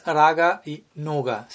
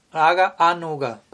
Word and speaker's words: raga anuga: raga anuga, no es raga y nuga, sino raga anuga, pero al juntarse las palabras obviamente se pronuncia como raga anuga.